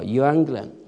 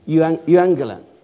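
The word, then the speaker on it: Evangelion (εὐαγγέλιον): 希腊文念作 Evangelion，是不是这样？ Evangelion 啊，那后面有个注，请你翻一下这个注啊。这个注是我很久以前呢写的注，多少年以前？我们 5、6年、6、7年以前， 2000年， 6、7年以前写的这个注啊。这个请你读一下福音，先说到福音了。福音的希腊文是啊 Evangelion，是由 Ru 就是好，Angelos 是传信息。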